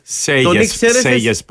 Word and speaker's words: Σέγες: Ο άλλος που ήρθε, είπαμε, είναι ο κύριος Σέλες. – Σέγες προφέρεται.